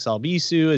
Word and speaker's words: Albisu: Go check out, well, you can follow me everywhere on all the social medias at Alex Albisu, as you can see up here above my head.